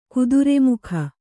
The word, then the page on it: ಕುದುರೆಮುಖ: kudure mukha